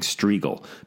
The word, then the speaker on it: Strigl: And I have a, I have this horrible name that, that people have such a trouble pronouncing Strigl because it has no E's in it. You know, it's just an I, S T R I G L.